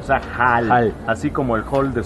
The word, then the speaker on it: Hol: Y los tanagarianos que llegan a lo que vendría siendo el Silver Age es H-O-L. – Ajá. – Hall. – Hall. Ajá. Se pronuncia igual, pero se no diferente. Se escribe diferente.